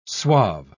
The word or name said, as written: suave